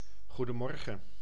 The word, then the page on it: goedemorgen: IPA: [ˌχu.dəˈmɔr.χə(n)]